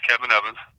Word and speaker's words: Kevin Evans: my name is Kevin Evans. Can't forget it, it rhymes.